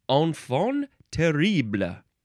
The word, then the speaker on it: enfant terrible: T-E-R-R-I-B-L-E. And it's pronounced terrible. This is a noun from 1851. It looks like the plural, you add an S to both of the words, but it's pronounced the same way.